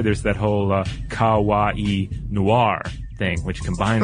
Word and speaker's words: kawaii: She says, "Hey guys, I just wanted to let you know that in Japanese, kawaii, K-A-W-A-I-I, well, that's the, you know, the English version of the kanji, cute is pronounced ka, like the kaing of a crow. So ka. Ka, like the cawing of a crow. Wa is in water, and I as in the letter E. So kawaii. – Kawaii. – Like Hawaii.